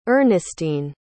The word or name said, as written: Ernestine